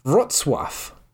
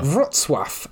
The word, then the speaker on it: Wrocław: But you'd an English speaker would say Rocklaw. But it's actually pronounced Wrocław. – Oh. – Wrocław.